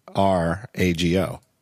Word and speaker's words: Farrago: We're celebrating the release of a new Rogue Amoeba app, Farago. Am I pronouncing that correctly? – You are. – Farrago. – That's right.